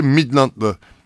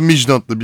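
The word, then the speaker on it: Midtjylland: eleme turunda da Beşiktaş deplasmanda Danimarka ekibi Midland'la ya da Midland'la birden fazla telaffuzu var.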